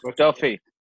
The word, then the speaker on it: Dauffe: Maybe Duffy. – MJ Duffy. Okay, that makes more sense. – Yeah. It's been pronounced a number of ways. It's Duffy, as in like D-U-F-F-Y, but spelled D-A-U-F-F-E.